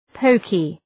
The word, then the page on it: poky: ‘pəʋkı